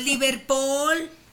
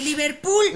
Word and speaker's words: Liverpool: Bueno, y también Liverpool, que resulta que no es Liverpool, que es Liverpool. – Liverpool. – Liverpool. – Liverpool. – Yeah. – Liverpool. – Es que cuando lo pronuncias bien, la boca no se abre.